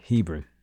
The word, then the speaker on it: Hebron: Hebron.